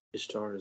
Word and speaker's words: Ishtar: Ishtar is how I pronounce it.